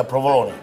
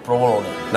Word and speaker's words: provolone: Provolone? – Yeah, provolone. Provolone. – Not provolone? – No, provolone. – Oh, I've said provolone my whole life.